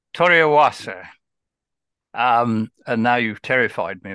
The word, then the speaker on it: Toriawase: Toriawase, I think, is how they pronounce it.